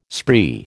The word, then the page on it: spree: American English